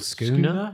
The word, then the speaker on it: schooner: It's what they call in Australia a scooter. Have you heard this? – Or schooner? – Schooner. That'd be it.